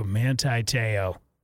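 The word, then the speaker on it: Manti Te'o: Monte Teo. I think I'm saying that right. I always screw that guy's name up. Monte Teo? Monte Teo. Manti. Manti. Ooh, Manti Teo.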